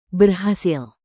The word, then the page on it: berhasil: indonesian